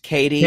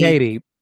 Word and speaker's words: Kattie: Yes, we know her name is pronounced Katie. – Katie. – But honestly, it is K-A-T-T-I-E, and phonetics tell us that the A becomes a short A or a double consonant, and her parents were either idiots.